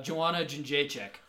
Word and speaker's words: Joanna Jędrzejczyk: Which is Weili Zhang versus Joanna Jędrzejczyk.